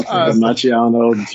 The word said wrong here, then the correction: Macchio: Jerry. So, what did you think of that? – Yeah. Rob Macchio. Are you hungry? Rob Macchio. – Yeah. Gotcha. Sorry. Added an extra syllable there.